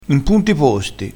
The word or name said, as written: punto